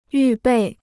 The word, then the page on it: 预备: yù bèi